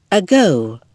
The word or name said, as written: ago